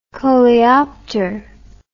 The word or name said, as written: coleopter